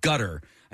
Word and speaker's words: Gutr: It's called Gutter. I think it's spelled G-U-T-R.